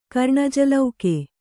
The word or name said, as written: ಕರ್ಣಜಲೌಕೆ